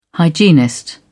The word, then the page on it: hygienist: British English